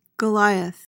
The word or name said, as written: goliath